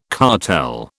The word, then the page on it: cartel: British English